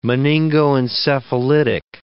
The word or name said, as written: meningoencephalitic